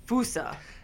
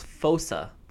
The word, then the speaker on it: fossa: The way Google pronounces it is Fosa. – Fosa. – Like foe, yeah. – And then sa.